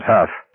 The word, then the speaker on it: Hough: Garrity, Baker, McVreeze, a struggling, hollow-eyed skeleton named George Fielder, Bill Huff, spelled H-O-U-G-H. Pronounce that, Huff, he had told Garrity much earlier on.